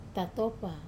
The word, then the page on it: tatopa: Wamesa